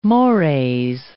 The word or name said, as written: mores